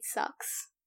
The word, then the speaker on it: SUX: How would you pronounce SUX? – It, it, it sucks. – Sucks. Yeah, six thousand sucks,